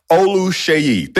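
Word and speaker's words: Oluseyi: Hakeem Alusei. Did I pronounce that right, Hakeem? – Close. Yeah. It's a O and a sh. Olusheyi. Think Olusheyi. Olusheyi. But it's Olusheyi. Yeah.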